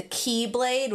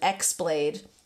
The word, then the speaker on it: X-blade: The counterpart, which protected the Kingdom Hearts from anything that wanted to use it for evil, was called the Keyblade with an X. It's spelt with an X. I always thought it was called an X-Blade when I was playing it because I didn't know the X was pronounced key.